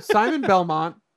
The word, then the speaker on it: Simon Belmont: So they officially announced that they will be adding Simon Belfort. – Who? – The guy from Castlevania? – Could you say his name again, please? – God damn. Simon Belmont. Jesus Christ.